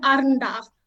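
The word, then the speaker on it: Arrernte: So you might know it, or people out there might know it as Larrakia and Arunda. However, it is pronounced Larrakia and then Arunda. So both of those is the roll of the R's. You've got to make sure you roll those R's.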